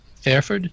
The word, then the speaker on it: Fairford: which takes place at RAF Fairford. Did I pronounce that correctly? – I said well done.